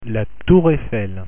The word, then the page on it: tour Eiffel: French